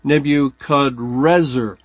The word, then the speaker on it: Nebuchadnezzar: Nebuchadrezzar. Instead of a N on the final syllable, it's an R, Nebuchadrezzar or Nebuchadnezzar. And it's a translation of the same Hebrew word.